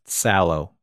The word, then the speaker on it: Salo: And we were informed actually on the episode where we covered it, it's actually pronounced Sallow.